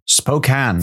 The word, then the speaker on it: Spokane: Spokane. – That's how it's pronounced. – Oh, it's not Spokane after all. – No, the E is misleading.